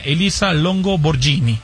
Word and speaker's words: Elisa Longo Borghini: Y el tercer lugar, ya de la tabla general, fue para la italiana Elisa Longo Borghini.